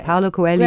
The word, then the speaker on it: Paulo Coelho: you know you've you've taken the works of you know Paul Quel. how do you say Paulo Coelho,